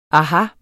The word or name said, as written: aha